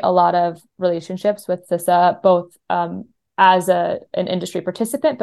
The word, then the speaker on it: CISA: It is pronounced CISA.